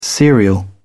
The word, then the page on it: serial: British English